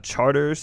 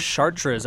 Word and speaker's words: Chartres: on Chartres Street, I think it was. – Chartres, I think, is the proper Street. French pronunciation.